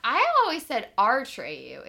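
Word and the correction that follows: Atreyu: It's a Treyu. – It's not our Treyu. – It's, it's your Treyu. A Treyu. Yeah, I was saying his name wrong, but from the Plains People, Hunters of the Purple Buffalo.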